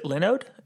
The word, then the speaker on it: Linode: Linode, Linode, Linux, Linux, you know how that goes. I think that the company itself pronounces it Linode, and their pitch is basically virtual hosting.